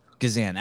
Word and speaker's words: Gazan: Gazan.